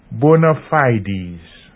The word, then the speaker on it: bona fides: Finally, we draw attention to the expression bona fides, spelt B-O-N-A-F-I-D-E-S. – Bona fides. Note that the pronunciation of the second word, supposed to be the plural of fide, is fides, or bona fides, not bona fides.